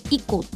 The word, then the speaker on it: ICO: ICO と書いて以降って読むんですね。